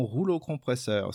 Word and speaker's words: rouleau compresseur: Malgré une défense plutôt solide, les Wallabies se transforment en rouleau compresseur, si je prononce bien, 15 à 7. – Très bien, rouleau compresseur, excellent.